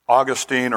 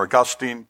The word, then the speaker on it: Augustine: Depending on how you say it, Augustine or Augustine of Hippo, he was a early bishop in the church.